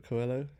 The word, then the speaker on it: Coelho: Paul, I can never pronounce his name. – Coelho. – Paula Coelho. Yeah.